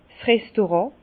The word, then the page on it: 's Restaurant: Bas Rhin; Herrlisheim